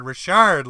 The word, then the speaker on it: Richard: And midway through the game, this guy's parents are blowing me up saying, no, it's Richard, like Maurice Richard. You're butchering his name.